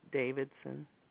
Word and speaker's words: Davidson: Janey, J-A-N-E-Y, Davidson. – Thank you very much. And Davidson is spelled D-A-V-I-D-S-O-N? – Yes.